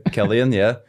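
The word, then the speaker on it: Cillian: Sorry, I couldn't even pronounce it right. Killian, yeah. C-I-A-L-L. C-I-L-L-I-A-N.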